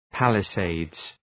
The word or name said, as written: palisades